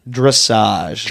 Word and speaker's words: dressage: Dressage. – Dressage with a D? – It's dressage. It's D-R-E-S-S-A-G-E. – Okay, it sounds dressage. Like croissant. It's like super, super cool equestrian stuff.